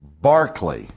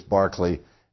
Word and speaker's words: Berkeley: And his name was George Berkeley, is how it's pronounced. But if you look at it, you think it reads Berkeley, because it's spelled like the university campus that we have in California that we pronounce Berkeley. But the pronunciation of his name is Berkeley.